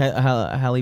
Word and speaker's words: Halle: Singer, how do I say this name? – Halle Berry. No, I don't know. – Halle. – Is it Halle, though? – Yes.